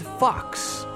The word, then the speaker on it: Phox: This music comes from the band Fox, that's spelled P-H-O-X.